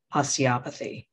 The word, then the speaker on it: osteopathy: osteopathy.